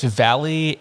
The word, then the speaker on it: Vallely: It's Valley, and there's also a an R that gets in there, like Valerie.